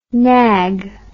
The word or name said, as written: knag